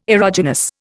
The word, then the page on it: erogenous: Indian